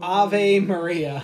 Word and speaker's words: Ave Maria: Ave Maria. Yeah, there you go. I said it right this time. – Not Ava.